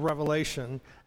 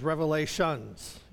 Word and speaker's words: Revelation: And we're talking about the it's interesting how that, like people pronounce the book of Revelation as revelations, you know, plural, but it's not plural, it's singular.